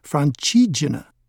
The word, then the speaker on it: Francigena: The name means roughly the way through France and is pronounced Francigena.